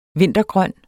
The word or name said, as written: vintergrøn